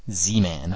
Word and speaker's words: Z-Man: Just pretend that there's Z-E-E, Z-Man.